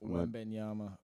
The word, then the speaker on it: Wembanyama: You absolutely butchered his name, by the way. – Isn't it Wembanyama or something like that? – I'm just going Wamba. I'm not doing all that. – Wembanyama.